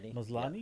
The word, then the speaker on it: Maslany: Tatiana, how do you say her last name? – Maslany.